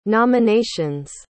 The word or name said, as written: nomination's